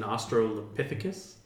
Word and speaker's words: Australopithecus: And in the new one, he's an Australopithecus.